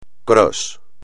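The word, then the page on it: cross: español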